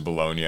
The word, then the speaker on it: Bologna: I had to go to Wikipedia and have it tell me how it's pronounced. – I'm not cultured. It's Italian. But my understanding is that it is Bologna.